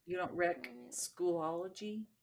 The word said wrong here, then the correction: Schoology: Schoology. – Schoology. I know. I keep saying it wrong.